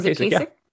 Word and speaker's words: Kasich: How do you say John Kasich? – John Kasich. Yeah.